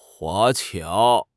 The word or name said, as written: 华侨